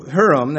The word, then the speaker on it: Huram: So Huram, that's how you pronounce it, finished doing the work that he was to do for King Solomon for the house of God.